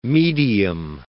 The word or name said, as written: medium